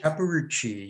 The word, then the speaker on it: Capparucci: Capparucci, yes.